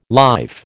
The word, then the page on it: life: American English